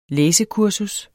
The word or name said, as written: læsekursus